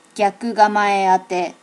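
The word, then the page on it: gyakugamaeate: native Japanese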